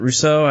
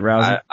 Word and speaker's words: Rousey: I enjoyed your comment going all, Rhonda, is it Rousseau? I never pronounce her name right. I hear it. Rousey. Is it Rousey? – I don't know if I've ever heard it. – Rhonda, I think it's Rousey. Ronda Rousey.